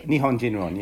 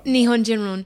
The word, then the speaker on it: Nihonjinron: In your piece you mentioned, and I'm not going to be able to pronounce it right, but you mentioned this word Nihonjinron, am I saying that right? – Nihonjinron, yeah. – Nihonjinron